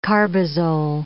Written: carbazole

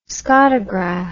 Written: scotograph

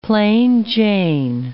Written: plain-Jane

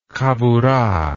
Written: chavurah

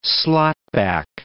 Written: slotback

